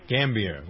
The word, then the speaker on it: Gambier: Do I pronounce that right? Gambier, right? – Gambier.